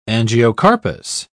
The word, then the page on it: angiocarpous: British English